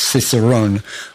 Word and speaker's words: Cicerone: And the key is in the name of the publisher, which we now know is pronounced Cicerone, which means actually what?